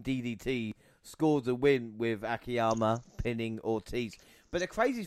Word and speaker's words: Akiyama: Monty, you're going to have to help me out here because it was Akiyama, the right way to say his name. – June Akiyama.